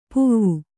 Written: ಪೂವು

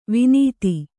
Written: ವನಧಿ

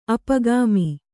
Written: ಅಪಗಾಮಿ

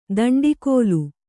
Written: ದಂಡಿಕೋಲು

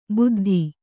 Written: ಬುದ್ದಿ

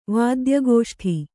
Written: ವಾದ್ಯಗೋಷ್ಠಿ

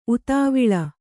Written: ಉತಾವಿಳ

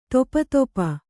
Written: ತೊಪತೊಪ

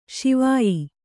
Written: ಸಿವಾಯಿ

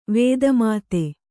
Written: ವೇದಮಾತೆ

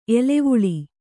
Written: ಎಲವಲ

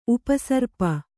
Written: ಉಪಸರ್ಪ

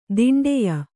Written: ದಿಂಡಿಯ

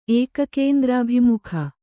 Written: ಏಕಕೇಂದ್ರಾಭಿಮುಖ

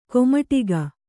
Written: ಕೈಮಡಗು

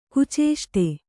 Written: ಕುಚೇಷ್ಟೆ

